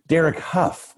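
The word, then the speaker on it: Derek Hough: You were partnered with Derek Howe. Is that what you say his name? Yeah. Huff? – Derek. – See, this is how out of touch I am with like contemporary anything. Derek Huff, tell us about that experience.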